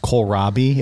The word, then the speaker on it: kohlrabi: I believe it's pronounced kohlrabi. It's a root vegetable.